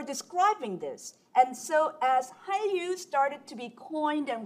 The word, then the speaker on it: Hallyu: Hallyu, some people spell it H-A-N-R-Y-U, others spell it H-A-L-L-Y-U, and literally is from Chinese, called the Korean Wave.